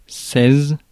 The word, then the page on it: seize: France; /sɛz/